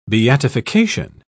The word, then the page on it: beatification: British English